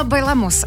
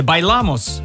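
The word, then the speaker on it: Bailamos: Представляете, они вообще не говорили по-испански, неправильно расставили ударение. И в слове «Байламос» оно должно было вот падать. На второй слог, да, не на последний.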